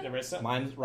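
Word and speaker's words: Nerissa: So, Narissa. Narissa? – Narissa? – Narissa. – Rhymes with Melissa.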